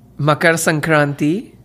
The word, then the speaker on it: Makar Sankranti: Makar Sankranti. – Makran Sankranti.